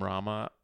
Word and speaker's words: Rahma: On TikTok, I'm at Kareem Rahma, which is my last name, R-A-H-M-A.